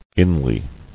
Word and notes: Inly: in·ly; In"ly

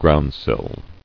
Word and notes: Groundsill: ground·sill

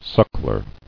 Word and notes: Suckler: suck·ler

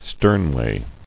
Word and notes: Sternway: stern·way

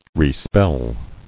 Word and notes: Respell: re·spell

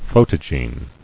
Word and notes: Photogene: pho·to·gene

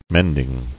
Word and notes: Mending: mend·ing

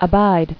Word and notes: Abider: A*bid"er

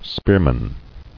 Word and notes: Spearman: spear·man

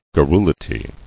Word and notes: Garrulity: gar·ru·li·ty

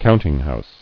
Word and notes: Countinghouse: count·ing·house

